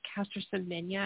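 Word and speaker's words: Caster Semenya: Castor, I think is the Castor Semenya, I think is how you would say her name.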